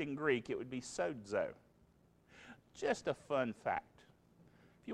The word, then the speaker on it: sozo: So that text of S-O-Z-O, a lot of people pronounce so-zo, but actually, again, it's transliterated. It's a Greek word that when you speak it in Greek has a little quiet D sound in it.